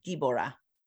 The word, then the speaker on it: Dibora: Well, and my daughter from Ethiopia she spells it instead of Deborah, spelling it like the way we know, D-E-B-O-R-A-H. Dib is D-I-B-O-R-A. And in Ethiopia, they pronounce it Deborah. And that's their version of Deborah, is Deborah.